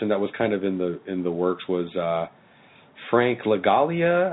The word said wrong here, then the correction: Langella: how do you say his name? – Frank Langella? The guy who played Skeletor.